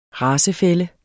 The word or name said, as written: racefælle